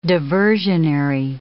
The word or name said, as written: diversionary